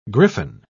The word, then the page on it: griffon: British English